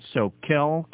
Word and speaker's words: Soquel: Box 969, Soquel, California. That's spelled S-O-Q-U-E-L. P.O. Box 969, Soquel, California, 95073.